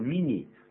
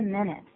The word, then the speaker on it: minute: 这个 minute 变成是 mini 或者 minute。